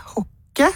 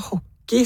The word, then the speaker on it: hocke: Men det er normert med H-O-C-K-E, hokke, som på tysk. Men man uttaler det som om det var hockey, sånn som i ishockey, altså det engelske ordet hockey.